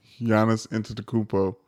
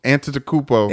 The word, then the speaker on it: Antetokounmpo: Giannis Antetokounmpo. – Antetokounmpo? – Antetokounmpo.